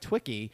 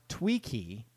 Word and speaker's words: Twiki: Twicky is actually, it says right here in parentheses, pronounced Tweaky. – Tweaky. – So I've been saying it wrong. Tweaky is how it's pronounced.